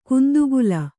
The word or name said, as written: ಗಂಧಕಲ್ಲು